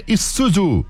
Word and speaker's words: Isuzu: É, Diego, você escreve Zuzu, mas a pronúncia é Isuzu.